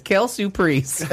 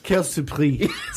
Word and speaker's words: quelle surprise: Say it correctly, because I only speak Lancome French. – Quelle surprise.